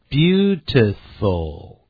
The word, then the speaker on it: beautiful: 念这个漂亮的这个形容词念成 beautiful, beautiful, 不要念成 beauty, 而不是 beautiful, beautiful, 记住鱼尾那个 o, 因为有个 l, 所以舌尖养成习惯呢要往上抵，抵到这个呢上牙齿的后头， beautiful, 现在我们就一块来听由 Bruce 跟 Jay 两位美籍老师就 Part 1 Dialogue 实用绘画所做的示范朗读。